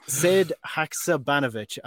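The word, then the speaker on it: Sead Hakšabanović: Sid Haksabanovic, I think that's a better pronunciation than earlier on in the podcast. Sid Haksabanovic, coming from Rubin Kazan, £5 million.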